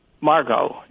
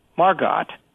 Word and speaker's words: Margot: And Jean Harlow would call her Margot. And so Margot Asquith once said, no, Jean, the T is silent, as in Harlow.